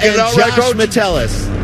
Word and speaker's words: Metellus: Am I saying that right? – Levert Hill. – Please give me. – Levert Hill and Josh Metellus. – Josh Metellus and Levert Hill. – Not even close.